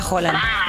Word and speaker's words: Haaland: Hoy día dijeron que se pronunciaba Haaland.